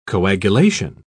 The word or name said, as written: coagulation